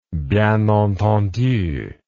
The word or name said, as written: bien entendu